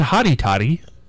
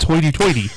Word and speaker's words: hoity-toity: It's not haughty-toddy. It's hoity-toity.